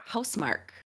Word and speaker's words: Housemarque: fact that Sony has acquired the Returnal developer Housemark, I believe is how you say it. I don't think it's Housemarque.